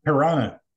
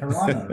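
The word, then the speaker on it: Toronto: Piranha. – Love it. – Piranha, right? There's no T's in it.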